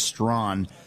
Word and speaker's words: Strachan: It's spelled Strachan, but it's pronounced Strawn, about his book, Christianity and Wokeness.